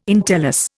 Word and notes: entellus: Indian

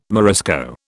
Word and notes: Morisco: British English

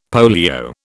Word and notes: polio: British English

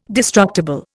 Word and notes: destructible: Indian

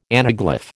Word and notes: anaglyph: American English

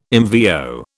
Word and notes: MVO: British English

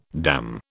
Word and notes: damn: British English

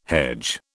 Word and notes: hedge: British English

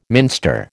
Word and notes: minster: American English

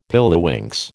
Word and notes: pilliwinks: American English

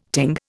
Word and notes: ting: Indian

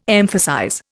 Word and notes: emphasize: Indian

